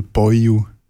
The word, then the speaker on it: Beinwil: Da kommt dann noch die Schwierigkeit von der Aussprache dazu, dass Beinwil zum Beispiel Boyu ist und gar nicht, das Wiel gar nicht richtig ausgesprochen wird.